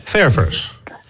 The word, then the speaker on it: ververs: Dat is ververs. – Ja, verder. – Dus je moet de klemtoon op de eerste E leggen.